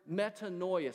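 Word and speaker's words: metanoia (μετάνοια): It's the word metanoia. Say that with me. Metanoia. Say it like you mean it. Metanoia.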